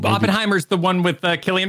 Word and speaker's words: Cillian: According to a guy who went how to pronounce on YouTube and did one of those, he said Cillian.